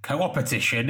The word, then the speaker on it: co-opetition: And you talk about actually two opposing styles of working, competition versus co-opetition, if I'm pronouncing it. Yeah, no, that's right.